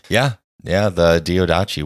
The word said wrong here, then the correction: Diadochi: I think it's Diadochi, I think is how you pronounce it.